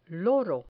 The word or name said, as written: loro